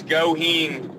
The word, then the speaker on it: Goheen: It's Goheen. It's just like it's spelled, but a lot of people butcher it, man.